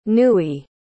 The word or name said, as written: newie